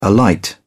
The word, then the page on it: alight: British English